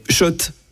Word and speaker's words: Schott: Oui, Schott et pas Scott. Ça fait des années qu'on prononce mal la marque.